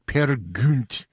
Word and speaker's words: Peer Gynt: Anitra's Dance from Peer Gynt by Edvard Grieg, or as Thomas Sundegaard says, Peer Gynt. That was his proper pronunciation on that one, and he would know, exactly.